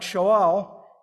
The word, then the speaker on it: Shaul: While it's hard to hear this similarity in the English pronunciation of their names, they're actually really close in the language they would have used, which is Hebrew. So Saul, which have been something like Shaul, it means to say, to ask, or to declare, or even to demand.